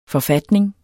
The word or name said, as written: forfatning